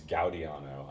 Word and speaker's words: Gaudiano: Yeah, Stefano Gaudiano, yeah. Yeah, it's Gaudiano. I know, I used to mispronounce it.